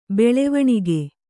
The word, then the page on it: ಬೆಳವಣಿಗೆ: kannada